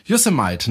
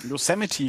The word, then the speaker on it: Yosemite: Yosemite, ne? Habe ich das richtig gesagt? – Yosemite, dachte ich. – Ja. Es ist schon wieder falsch gemacht. Yosemite.